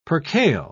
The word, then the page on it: percale: British English